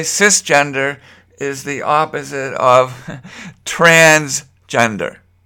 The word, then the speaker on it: cisgender: Now, we have another word, and I'm not sure the pronunciation is cisgender. Is that correct? Cis, C-I-S, or cis, but I've heard it called cis.